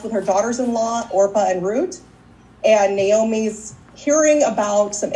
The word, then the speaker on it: Ruth: While she was there, her two sons also married Moab women, and one of them was named Orpah, the other one was named Ruth, or Root in the Jewish pronunciation.